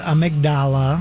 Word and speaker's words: amygdala: How to pronounce amygdala.